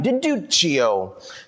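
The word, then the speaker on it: di Duccio: You got to say it like a good Italian. Deduccio, who agreed to their terms.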